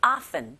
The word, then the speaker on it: often: It often 보다는 open 하시면 안 되고 often, often, f, f 발음 들어주셔야 돼요. p가 아닙니다.